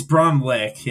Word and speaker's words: Braunlich: It's Braunlich.